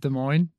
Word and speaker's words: Des Moines: Is that how you say it? I have no idea. – Is it Des Moines? – Yep.